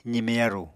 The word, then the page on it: zime-yaru: apatani